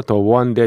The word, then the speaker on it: The One: 이 에어서프라이가 유일하게 팝 차트의 음, 주간 단위 차트에서 넘버 원을 기록한 유일한 곡이 바로 더원 That y 입니다 어떤 사람들은 The One That You Love라고 하는데 더 원이 맞습니다. 왜냐하면 그 원이라는 발음 앞에 음가에 W라는 자음이 들어있기 때문에 더 원이라고 합니다. 원래 앞에 모음으로 시작하면 D라고 있잖아요. 근데 여기는 음가로 봐서는 자음 W 이게 있기 때문에 예, The One이라고 하는 게 맞습니다.